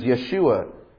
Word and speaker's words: Yeshua: The Hebrew pronunciation is Yeshua.